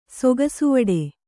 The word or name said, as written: ಸೊಗಸುವಡೆ